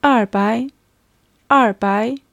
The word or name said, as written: erbai